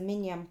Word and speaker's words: minyan: Yeah, not, no, it's spelled M-I-N-Y-A-N.